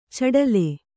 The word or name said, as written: ಛಿಟಿಲ್